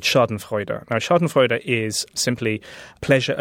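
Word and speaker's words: Schottenfreude: First of all, am I pronouncing the name of the book correctly? – Well, I pronounce it Schadenfreude. – Of course you do. – But I've been told I have a terrible German accent, so God knows if I'm right.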